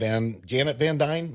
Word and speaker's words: Van Dyne: We get to see this whole world within a world because, you know, last time we saw Janet Van Dyne, am I saying that right? – Yes.